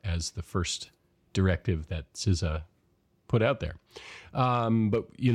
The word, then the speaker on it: CISA: So I think maybe the highest profile part of this was the establishment of what's everybody's favorite agency to debate the pronunciation of, CISA, which is the correct pronunciation, by the way. CISA was formed in 2019.